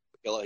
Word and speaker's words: Piccillo: From BC Sanders and Jason Piccillo. I think I said that right, right? Jason Piccillo. He's got. – Sounds right.